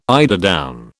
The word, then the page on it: eiderdown: British English